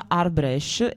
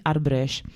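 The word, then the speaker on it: arbëresh: La stessa parola arbresh si pronuncia arbresh, e questa E è molto simile anche alla E barese che dicevamo prima, quindi arbresh.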